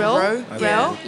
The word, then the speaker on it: Rowe: Adam Rowe.